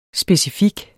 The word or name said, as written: SPECIFIKT